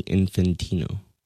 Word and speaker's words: Infantino: Giannini Infantino.